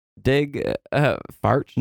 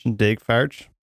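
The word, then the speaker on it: Daeg Faerch: Dig. Farch. – Dig Farch.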